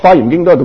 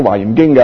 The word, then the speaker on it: 华严经: 咁所以呢，有啲人做咗几十年呢、这个花严经都系读作呢个华严经嘅，咁佢明白咗，咁就应该改正啦。